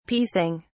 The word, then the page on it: piecing: British English